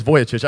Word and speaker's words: Vujicic: This is Nick Vucicic, whatever his name is, okay? Vujicic, Vujicic, Vujicic. There it is, Vujicic.